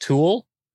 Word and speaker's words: Thule: I call it Tool, T-H-U-L-E. How do you say that? Tool. Some people say Thule. Some people say Thule. Some people say Tool.